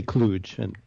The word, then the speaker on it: Cluj: Cluj, is that how you pronounce it? Cluj? – People differ about that, but that's fine. I would say Cluj.